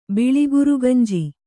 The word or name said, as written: ಬಿಳಿಗುರುಗುಂಜಿ